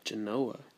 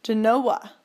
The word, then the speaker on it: Genoa: Genoa. – Genoa. – That's how they say it here.